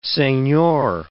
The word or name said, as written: senor